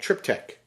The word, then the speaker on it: triptych: It's called triptych. – Yep. – And it is spelled T-R-I-P-T-Y-C-H.